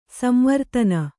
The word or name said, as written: ಸಂವರ್ಧನೆ